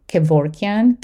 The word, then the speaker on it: Kevorkian: My name is spelled Noura, N-O-U-R-A, Kevorkian, K-E-V as Victor, O-R-K-I-A-N.